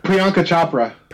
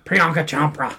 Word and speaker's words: Priyanka Chopra: Priyanka Chopra. – Priyanka Chopra.